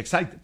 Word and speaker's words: Exide: Se escribe Exide, se pronuncia Exide, dentro decía el comercial de las baterías.